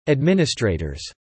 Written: administrators

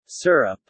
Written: syrup

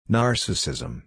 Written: narcissism

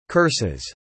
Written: curses

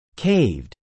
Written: caved

